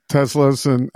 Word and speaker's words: Tesla: If someone has an idea for, Tesla wants to sponsor us or something and give us Teslas. And by the way, I only know it's pronounced Tesla because that's the way Elon Musk pronounces the name of his company.